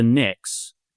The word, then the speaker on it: Pnyx: But first, let me correct you, Dan, that it's pronounced the Knicks. And not just because the Knicks have game two tonight, and it's a really big night. So go Knicks. But it's also, that was the, it's spelled PNYX because that was the assembly in ancient Greece where bills were debated.